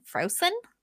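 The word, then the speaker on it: Frocin: So they decide that they're going to go to this dwarf named Frosin, I think is how it's pronounced. It's F-R-O-C-I-N, Frosin.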